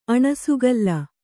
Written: ಅಣಸುಗಲ್ಲ

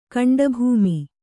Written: ಗಂಡುಭೂಮಿ

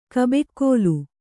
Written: ಕಬ್ಬಿಕೋಲು

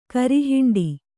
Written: ಕರಿಹಿಂಡಿ

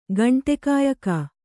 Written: ಗಂಟೆಕಾಯಕ

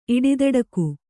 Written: ಇಡಿದಡಕು

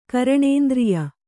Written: ಘ್ರಾಣೇಂದ್ರಿಯ

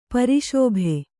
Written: ಪ್ರಸಭ

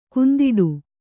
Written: ಕಂದೊಡಿ

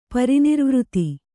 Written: ಪರಿನಿರ್ವೃತಿ

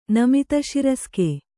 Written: ನಮಿತಶಿರಸ್ಕೆ

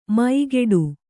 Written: ಮೈಗೆಡು

